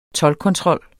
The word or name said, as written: toldkontrol